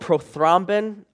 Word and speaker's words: prothrombin: Well, again, recent studies show that there's a protein present in blood plasma called prothrombin. Are there any doctors or nurses out there? Am I pronouncing that right? Prothrombin? I got a nod yes.